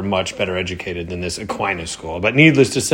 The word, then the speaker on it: Aquinas: Aquinas is how you say it.